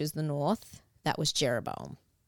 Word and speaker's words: Jeroboam: And there's Rehoboam and Jeroboam. – Yes, that's it.